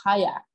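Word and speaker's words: qajaq: in inuktitut their language they would pronounce it as hayak more with a q sort of sound um, and it's something that has gone global.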